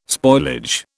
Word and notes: spoilage: British English

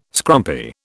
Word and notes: scrumpy: British English

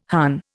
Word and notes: Hun: Indian

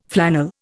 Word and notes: flannel: Indian

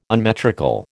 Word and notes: unmetrical: American English

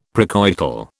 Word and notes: precoital: British English

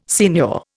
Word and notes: signor: Indian

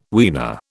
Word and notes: weaner: British English

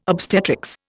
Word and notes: obstetrics: Indian

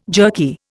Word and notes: jerky: Indian

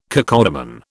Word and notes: cacodemon: British English

